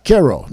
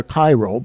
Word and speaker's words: Cairo: such as Cairo. Now, it's pronounced elsewhere Cairo, but we pronounce it Cairo, Thebes, and Karnak.